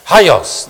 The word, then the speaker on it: hagios: Some pronounce it hagios, but if you're from Greece, they'd say, no, it's hagios, hagios. The G is very soft, hagios.